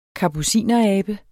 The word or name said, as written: kapucinerabe